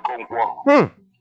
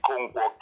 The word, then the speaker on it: Konkwo: My name is Ehani Konkwo. – What do you say your name? – Ehani Konkwo, K-O-N-K-W-O.